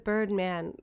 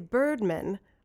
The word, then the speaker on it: Birdman: I pronounce it Birdman because it's he's a superhero. – Yes, correct. – A lot of people say Birdman, almost like it's like a last name. – Isn't that like plural though, Birdman? – Oh, like M-E-N? – Yeah, Birdman.